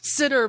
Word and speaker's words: Synnar: Sinner. – I hate it's comic books. – It's pronounced sitter, but it is spelled S-Y-N-N-A-R.